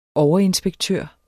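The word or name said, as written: overinspektør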